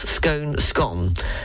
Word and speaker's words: scone: The YouGov survey in 2016 actually also revealed that most of the nation follows the proper way anyway, with 51% of Britons pronouncing it correctly and 42% pronouncing it rhyming with bone. The same survey showed that more people in the north of England and Scotland use the proper pronunciation than in the Midlands and in London. Cambridge University's Great Scone Map, also published in 2016, confirms this regional divide. The map shows that approximately 50 to 60% of Surrey residents pronounce scone, scone.